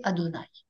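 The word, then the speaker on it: Adonaï: Parce que le nom imprononçable dans la Bible hébraïque est remplacé par les voyelles du mot Adonai, le Seigneur. Et on décide de prononcer Adonai.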